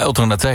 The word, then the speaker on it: Ultra Naté: Het was Free van, ik kan die naam nooit zo goed uitspreken, Ultra Naté. – Ja, Ultra Naté inderdaad.